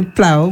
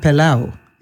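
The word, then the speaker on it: pelau: So you say plow, but it sounds to me like pilau. – Yes, so you pronounce it differently.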